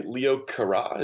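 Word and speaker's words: Leo Carax: Holy Motors, which is by Leo Carra. Is that the right pronunciation? – I believe that is correct. Yes.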